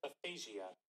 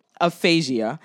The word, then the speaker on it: aphasia: aphasia. That's the word, aphasia.